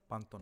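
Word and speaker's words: Pantone: ono kao panten, kako se zove boje koje izbacu svake godine? Sa pan... – Panton. Panton. – E, dobro, ne znam da izgovorim.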